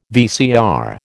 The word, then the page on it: VCR: American English